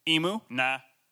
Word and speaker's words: emunah: So, like, pretend you're at a party, and somebody brings you a plate of food, and it's clearly a bird, and like, what is it? Emu? Nah. So, that's how it's spelled. Emu? Nah. So, emunah, Hebrew for faith, it carries no connotation for blind belief.